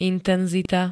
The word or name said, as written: intenzita